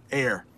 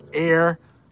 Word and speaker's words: heir: So basically, air is pronounced like this, air. And that means someone who will inherit money or property after someone dies. They are the heir to the throne. Heir.